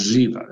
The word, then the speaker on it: Ziba: Remember, the B in Hebrew has a V sound. So it's Ziba. That's how it's pronounced.